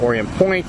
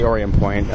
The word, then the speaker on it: Orient Point: I thought it was Orion for the longest time. – On a ferry that used to go there, whatever. But you don't pronounce the T if you're from here.